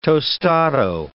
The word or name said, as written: tostado